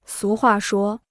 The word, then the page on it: 俗话说: sú huà shuō